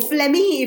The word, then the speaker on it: phlegm: Yeah, dairy products make you more mucousy, more phlegmy, if you will. I love the word phlegm because it's got that silent G in it.